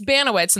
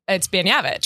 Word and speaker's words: Banowitz: It's Banowitz, and that's what I have been told my name was my whole life. But I did know that they pronounce it. I always knew it was an inaccurate way to pronounce it. But now that I'm on the internet, I got people from Poland being like, what are you? It's Banyevich.